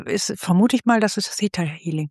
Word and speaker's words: Theta Healing: Theta Healing, spricht man das eigentlich so? Weil es ja ein TH am Anfang ist, könnte man ja auch Theta Healing sagen. – Es wird immer Theta Healing gesagt.